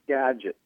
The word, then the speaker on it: Skagit: it's actually pronounced Skagit, the racetrack out here.